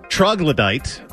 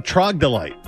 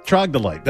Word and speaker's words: troglodyte: And also, thanks to all the emailers and tweeters who have informed me, I have been pronouncing troglodyte the wrong way my whole life. I've been saying trogdolite. Troglodyte. That's what I referred to the Philadelphia fans earlier as, the 76. Yeah, it's troglodyte. – Troglodyte. – That's the correct pronunciation, stupid.